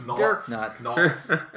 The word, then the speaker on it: Naudts: I don't even know if I'm saying it right. – Dirk Nuts. – Nauts. N-A-U-D-T-S. To create this unique bottle conditioned beer,